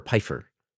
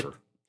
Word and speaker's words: Phifer: Is it Phifer or Pfeiffer? – Mekhi Phifer. – I never knew how to say it. – It's Phifer.